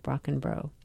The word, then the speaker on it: Brockenbrough: And the author is Martha Brockenbro. And I'm going to spell that because it's long and complicated. Brockenbrough. Think of it as rock and roll, Brockenbro.